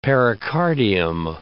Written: pericardium

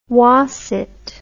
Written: whassit

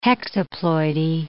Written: hexaploidy

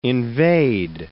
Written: invade

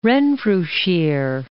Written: Renfrewshire

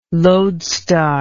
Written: loadstar

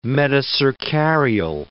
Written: metacercarial

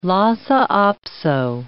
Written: Lhasa apso

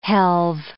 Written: helve

